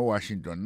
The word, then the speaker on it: Washington: Si n'ap mette accent sou accent kò, ou apwè ke mo Washington nan, accent tombe sou premier syllabe la.